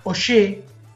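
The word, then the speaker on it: Augé: Y hay un libro muy, muy lindo de Marc Ogier que se llama Elogio de la bicicleta, que se escribe Auge. A-U-G-E.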